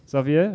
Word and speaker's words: Xavier: They are doing a Javier Dolan program. How do you pronounce that, somebody? Correct me. – Xavier Dolan. – Xavier? Okay, there we go.